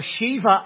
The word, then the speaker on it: Sheba: Others say that no, Sheba or Shiva, as it would have been pronounced in the Hebrew tongue, it was to the south of Israel in the Arabian Peninsula.